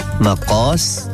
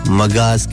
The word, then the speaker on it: مقاس: اصل میں اس کو جو تحریری زبان ہوتی ہے اس میں اس کو اس طرح لکھتے ہیں, میم قاف الف سین مقاس, لیکن جب ہم اسے اپنے عام لہجے میں یوز کر رہے ہوتے ہیں تو ہم اس کے تلفظ کو تھوڑا قاف کو گا بنا دیتے ہیں, مگاز کہتے ہیں.